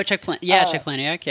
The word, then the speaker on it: Palahniuk: Oh, Chuck Palahniuk.